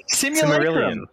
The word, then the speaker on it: simulacrum: Google says simulacrum. That's what I said.